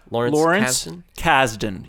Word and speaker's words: Lawrence Kasdan: I wondered if you knew if this movie was part of that, because I don't know that much about Lawrence Kasdan. Am I saying his name right? – Lawrence Kasdan, yes.